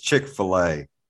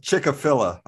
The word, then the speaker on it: Chick-fil-A: But by the way, Money Mike, it's Chick-fil-A. Dumbass. Learn how to pronounce it. Why? Sorry, go ahead. – What do you say, fillet? – Chick-a-filla, I think is how he pronounced it on a Zoom.